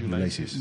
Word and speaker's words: Ulysses: He tenido que mirar cómo se pronuncia en inglés, ¿eh? Ulysses. – Muy bien, muy bien.